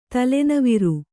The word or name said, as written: ತಲೆನವಿರ್